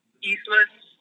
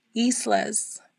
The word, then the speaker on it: Islas: Islas. – What is it? – Islas. Yeah, I-S-L-A-S.